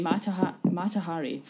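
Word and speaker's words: Mata Hari: Today, I am talking about the glamorous, ominous, yet tragic life of Matihara. Sorry, Matahari. Fuck off. I said it wrong.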